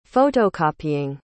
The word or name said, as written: photocopying